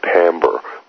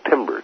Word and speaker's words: timbre: We began to touch on the idea of the color of sound, which is known as the timbre, which is spelled timbre, T I M-B-R-E, and this has to do with harmonics.